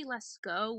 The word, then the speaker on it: Lascaux: Yeah, it's pronounced actually Lascaux, which I found out the hard way after doing a whole presentation on it.